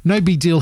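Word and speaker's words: NoBigDyl: And again, it's spelt all one word, but Deal is D-Y-L if you're looking for it online. We'll have the link in the show notes. No Big Deal, his latest single is called One Way.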